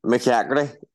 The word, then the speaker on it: McCaugherty: I hope I spelled pronounced his surname correctly. – McCaugherty. Everybody gets it wrong. It's sweet. It's a long line talking to you. – Is it McCaugherty? – McCaugherty. It's a modern name.